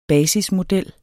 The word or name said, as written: basismodel